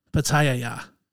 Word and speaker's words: pitaya: I'm adding an extra ya in there. – Pitaya. – No, I was saying it right.